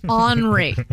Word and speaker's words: Henri: His name is spelled H-E-N-R-I. You might be like, yo, Henry's coming. It's Henry. Okay? Make it sound rich.